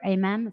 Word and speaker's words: Amen: Now you're involved in something called Change Your Brain Foundation with Dr. Amen. Is that how you pronounce it? – Yeah.